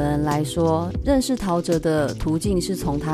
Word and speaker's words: 陶喆: 那里面呢，人物的轮廓被模糊了，在 David 跟 Tao 中间有一个巨大的惊叹号，然后底下写陶喆。这个名字刚出来的时候，很多人都没有办法把他的名字念对，所以大家都念成陶吉吉。